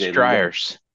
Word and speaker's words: Dreher: So, and it's called, and I'm probably butchering how you say it, but it's Dreher, D-R-E-H-E-R. – I think it's Dreher's.